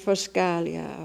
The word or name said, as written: foscalia